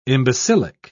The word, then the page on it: imbecilic: British English